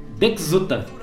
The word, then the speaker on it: Deczuta: O pessoal que é descendente polonês, alemão, é um sobrenome meio complicado. Dexuta. – É Dexuta, é assim que se pronuncia? – Isso, é, como se escreve.